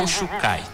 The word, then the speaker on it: Kos Chukai: Inclusive, nesta sexta, vai acontecer um encontro entre praticantes do Aikido, reunindo Rio Branco e Porto Velho, o Kos Chukai. Se eu tiver, eu falei o nome errado, por favor, me corrija. – É tranquilo. – É a pronúncia correta mesmo? – Kos Chukai.